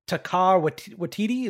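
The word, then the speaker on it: Taika Waititi: They just announced, I'm going to, I don't mean to butcher his name, but I, I believe it's Takar Watiti. Is that how you say his name? – The oh, yeah.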